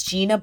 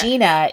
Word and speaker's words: Jeena: You spell it B-L-O-O-M, like a flower blooms. And then okay. Gina is J-E-E-N-A. So it's like Gina Davis, but with a J.